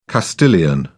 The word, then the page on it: castilian: British English